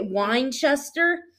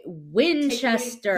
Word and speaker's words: Winchester: Okay, Winchester. – Say it, but without it sounding like wine. – Okay, Winchester.